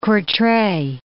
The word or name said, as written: Courtrai